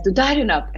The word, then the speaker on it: Dudarenok: And he says, Dudarenok.